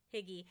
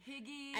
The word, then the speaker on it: hygge: And pronounced hygge. – Hygge. – Not hygge. – Higgy. – Higgy. – I always think getting higgy with it.